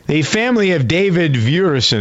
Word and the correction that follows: Duerson: Is that how you pronounce this football player's name? V-E-V-U-E-R? – Duerson, actually. – What is D. – it? – That's a typo. It's Duerson.